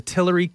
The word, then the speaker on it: Tillery: You know, but hey, so today we have special guests on from the Tillery Kitchen and Bar. Is that the correct way to say it? – That is correct.